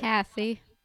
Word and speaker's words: Cassie: It's Cassie. – Cassie, that's what I said. – Yeah. Oh, I thought you said Casey. – Oh, shit, did I? – Yeah, Cassie, you said Casey.